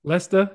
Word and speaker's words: Leicester: And he is based now in London, formerly of Leicester. Am I saying that right? – Leicester, yeah.